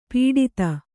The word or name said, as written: ಪುಟಿತ